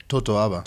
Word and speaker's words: totoaba: and how do you say it, the Tabora? – Totoaba. – Totoaba.